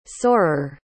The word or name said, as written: soarer